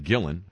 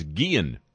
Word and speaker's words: Guillen: During last Sunday's Le Show, you mispronounced the name of Chicago White Sox manager Ozzie Guillen. I said Guillen. I did indeed. The correct pronunciation is Guillen.